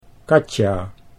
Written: Katja

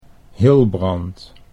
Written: Hilbrand

